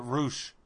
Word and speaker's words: Rush: You know, I think he pronounces it Roosh.